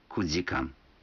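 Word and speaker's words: кудикан (九時間): Некоторые цифры в этих случаях произносятся не так, как обычно. 4 часа – это йодикан, 7 часов – ситидикан, а 9 часов – кудикан.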